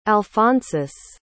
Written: alphonsus

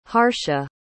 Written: harsha

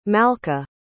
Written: malca

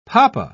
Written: papa

